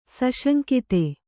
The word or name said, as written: ಸುಸಂಗತ